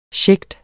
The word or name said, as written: sjægte